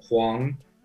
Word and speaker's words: Huang: It means big yellow, Huang. So in Chinese, that's how you say it.